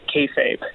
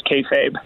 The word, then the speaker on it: kayfabe: So I should let's start by pronouncing it kayfabe. It's a funny word. I guess we'll probably get into what it all means in a moment, but it is kayfabe.